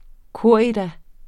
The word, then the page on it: corrida: ˈkoɐ̯ˀida; koˈʁiːða